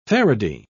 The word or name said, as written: faraday